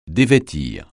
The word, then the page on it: dévêtir: francais